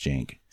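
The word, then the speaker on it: Cenk: And then there's also the host of the Young Turks, who his name is spelled with a C, but it's pronounced Jank. – So to us, it looks like a K sound, but it's a J sound.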